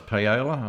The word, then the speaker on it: paella: He loves paella and all that sort of thing.